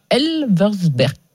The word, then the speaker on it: Elversberg: Le Bayer Leverkusen a été battu 3-2 par Elversberg.